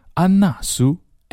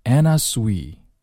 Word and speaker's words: Anna Sui: 安娜苏 Anna Sui Anna Sui，不要真读成安娜苏啊，Sui。